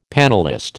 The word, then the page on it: panellist: American English